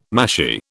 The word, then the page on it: mashie: British English